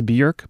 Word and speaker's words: Björk: And that's with the lead singer Björk, who actually is more closely pronounced Björk, but we'll get to that in just a moment.